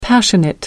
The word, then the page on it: passionate: American English